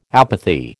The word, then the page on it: apathy: American English